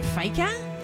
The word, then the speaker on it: fika: Yeah, there is a secret tradition in Sweden, and it's pronounced fika.